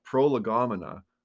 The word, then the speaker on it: prolegomena: I have trouble with this word, prolegomena. I think I said that right. – Yeah. – Pro, lego, mina, whatever, say it however you want.